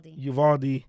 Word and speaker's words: Uvalde: Uvalde.